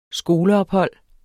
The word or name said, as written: skoleophold